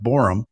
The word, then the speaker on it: Boreham: If you go on Amazon and type in My Christmas Book and his last name, B-O-R-E-H-A-M, Borham, but it's pronounced Borum.